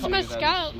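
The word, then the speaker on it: mescaline: That's no, it's mescalin.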